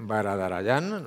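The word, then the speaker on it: Varadarajan: No es complicado, no es fácil el apellido de Tunku, es Baradarayan. – Sí, perfecto. – Baradarayan, lo he dicho bien.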